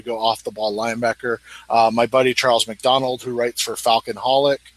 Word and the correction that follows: Falcoholic: or however you pronounce it, the Atlanta Falcons. – The Falcoholic. – Falcoholic.